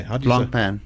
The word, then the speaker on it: Blancpain: Blancpain.